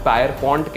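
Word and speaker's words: Pierpont: मैंने शायद इसको गलत प्रनाउंस किया होगा इसको कुछ पायर पॉइंट कहते हैं कोई पियर पॉइंट कहते हैं मैं स्पेलिंग बता देता हूँ P I E R P O N T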